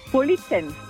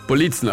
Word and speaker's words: Politzen: Politzen. – Politzen, also die Betonung ist auf dem I.